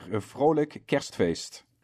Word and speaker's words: Vrolijk Kerstfeest: And I would like to wish you Vrolijk Kerstfeest. How do you say that again? – Yeah, Vrolijk Kerstfeest. – Vrolijk Kerstfeest.